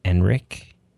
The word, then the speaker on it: Ehrenreich: I never say this guy's name right. Alden Ehrenreich? – I think that's how you say it, yeah.